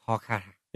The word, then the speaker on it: Kolkata: The actual pronunciation in Bangla is Kolkata with soft K's and a soft T. I'm not really sure what that means. – Kolkata. – Kolkata.